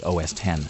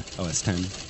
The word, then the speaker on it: OS X: I've been saying OS X for like forever and ever, but apparently that is supposed to be OS 10. – OS 10.